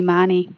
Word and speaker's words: Mahony: I thought it was Mahony. – It is pronounced that way often. It's actually Mahony.